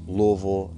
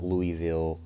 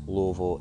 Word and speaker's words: Louisville: He's from my home city of Louisville, Kentucky, which, yes, it's pronounced Louisville, not Louisville or Louisville. It's Louisville. If you live here, you say Louisville.